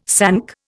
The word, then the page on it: sank: Indian